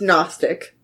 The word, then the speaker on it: Gnostic: I think it's Gnostic. – The G is silent.